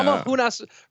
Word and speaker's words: Namajunas: Well, first of all, tell me that you really think that that's how you pronounce the last name of Rose. – Just really go through that one again. Nama Junas. That, that was better. Yeah. Namahunas. Rose yeah. Namajunas.